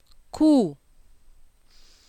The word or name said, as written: Kuh